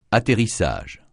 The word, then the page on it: atterrissage: francais